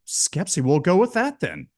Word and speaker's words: Schepisi: I looked at the director's name as I would personally in my head was pronouncing it Fred Schepisi, but I think you pronounced it differently. Do you know offhand? Did you hear in an interview by any chance how it is pronounced? – I looked it up and phonetically it said Skepsi.